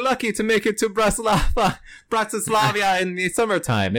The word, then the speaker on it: Bratislava: Bratislava.